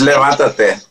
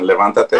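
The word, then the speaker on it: Levántate: His book, Stand and Levantate, how do you pronounce that? – It's Levantate. That's the Spanish version, Levantate.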